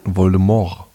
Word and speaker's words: Voldemort: Voldemort. – Voldemort. – Da lassen das T so hart weg, dass mich das hier... The T is silent.